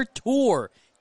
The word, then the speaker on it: Tur: From Junior Tour. T-U-R is how you spell his last name, but pronounced Tour as here comes the 2-1.